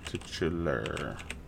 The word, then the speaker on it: titular: How do you spell it? T-I-T-C-H-I-E-R? – No, T-I-T-U-L-A-R. – Okay, so titular. That's what I had the first time.